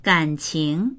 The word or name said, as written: gan3qing2